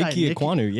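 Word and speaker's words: Ike Ekwonu: Number five, New York Jets, Ike Ikwanu. I'm sorry if I butchered her name. – No, you nailed it. It's Ike Ikwanu.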